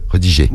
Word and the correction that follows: rediriger: Tu as oublié une syllabe. Rediriger. Tu as oublié rediger. Non, c'est rediriger sur Snap.